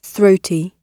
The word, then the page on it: throaty: British English